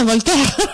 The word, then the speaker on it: Voltaire: Ah, Voltaire.